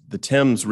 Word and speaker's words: Thames: back when the Thames River, that's how they say it over there, which flows in front of through London, that whole river froze.